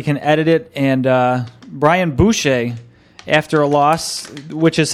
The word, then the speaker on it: Boucher: Brian Boucher, pronounced Boucher, born January 2nd, 1977, is an American professional ice hockey goaltender for the Philadelphia Flyers of the National Hockey League.